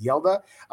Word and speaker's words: Hjalte: It's spelled H-J-A-L-T-E. It's actually pronounced Yelda.